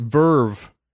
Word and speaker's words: VRV: Oh, VRV is pronounced Verve.